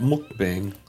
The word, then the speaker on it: mukbang: I'm ready for some mukbang. Don't you have to say it like that? Mukbang. – I think that's the pronunciation. Yeah, mukbang.